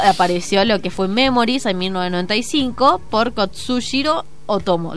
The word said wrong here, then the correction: Katsuhiro Otomo: ¿Lo dije bien? – Katsuhiro Otomo. – Katsuhiro, ahí está.